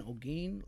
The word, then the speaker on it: Olgin: My YouTube channel is actually under my name, Jordan Olgin. Last name is spelled O-L-G-I-N. Snapchat would also be my name. Twitter be my name. Actually, no. Twitter is J-O-7-8-9-3.